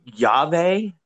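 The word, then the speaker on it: Llave: He wrestled this really cool, like they call it like Lave, or I might even be pronouncing that wrong. – Is it Yave? – Yave. – Is it LL? Yeah.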